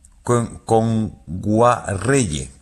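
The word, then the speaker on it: Kngwarreye: Emily Kame Nwayere, lo he dicho mal. – Emily Kame. – Con Guarreye.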